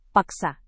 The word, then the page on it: paksa: Indonesian